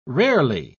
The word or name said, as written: rarely